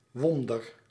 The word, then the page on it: Wunder: ˈvʊndəʁ; ˈvʊn.dɐ